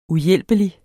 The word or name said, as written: uhjælpelig